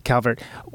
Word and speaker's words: Calvert: Yeah, you are Calvert, very popular name in the United States.